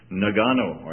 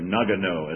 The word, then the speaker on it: Nagano: They're working in a city, Ayuma, which is about an hour north of Nagano, or Nagano, as the Americans pronounce it, where the Olympics were last winter.